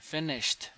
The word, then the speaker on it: finished: Finished. Not finishing. Finished. – Finish it. – Okay. I can still hear you say finish it. One more. Just T sound at the end.